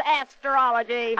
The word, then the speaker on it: astrology: Yeah, like that there asteriology of yours, I suppose. – Eli, how many times have I got to tell you that it's pronounced astrology? – I think Webster says it's astrology, Patience.